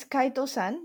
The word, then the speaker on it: chitosan: It's called chitosan. Am I saying it right? – It's chitosan, yes. Chitosan, so you're right.